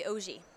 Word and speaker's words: Auger: But anyway, so yeah, so grew up, my last name's actually Ogie, which is French Canadian, but I say Auger because no one could figure it out, and then everyone was spelling my name wrong.